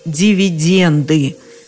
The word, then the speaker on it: дивиденды: Как мы уже выяснили, на конце в слове «дивиденд» пишется буква «Д». А стало быть, у нас никаких других вариантов нет, кроме как «дивиденды». Глухому «Т» взяться там неоткуда, никаких дивидендов.